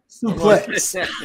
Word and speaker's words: suplex: And, you know, and I'll show him what we call that move, a souffle. – Suplex. – Suplex. – The X is, yeah, in wrestling, the X is pronounced.